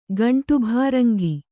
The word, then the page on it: ಗಂಟುಭಾರಂಗಿ: gaṇṭu bhāraŋgi; Kannada